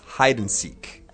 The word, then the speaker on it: hide and seek: But just remember, guys, that the and, we don't really pronounce the and very strongly. So it's just hide and seek.